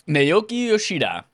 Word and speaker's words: Naoki Yoshida: Naoki Yoshida.